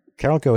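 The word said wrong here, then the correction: Carolco: Carralco is actually how it's pronounced.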